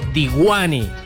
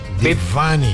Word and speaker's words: Divani: Pero en realidad, y la pronuncian como Disco Diwani. – Diwani. – Es Divani. – Divani. – Exactamente.